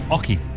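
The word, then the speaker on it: oche: It's pronounced Oki.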